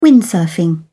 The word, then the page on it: windsurfing: British English